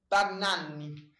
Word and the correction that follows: Bagnaia: Bagnaia. – I'll tell you what. Well done on the first name.